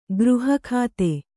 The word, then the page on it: ಗೃಹಖಾತೆ: gřha khāgte